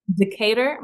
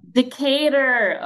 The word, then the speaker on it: Decatur: Decatur Montessori School. – Decatur.